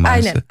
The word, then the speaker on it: Mainz: Mainz'a mı başvurdunuz? Doğru mu telaffuz ettim Mainz'ı? – Aynen.